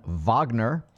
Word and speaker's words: Wagner: Tony says, for the record, I like Mozart, even Wagner. Oh, yeah, that's right. I said it right.